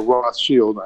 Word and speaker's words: Rothschild: There is a famous story in the wine world where someone asked Baron Philip de Rothschild, or Rothschild, I suppose is the proper pronunciation, what was the greatest wine he'd ever drank.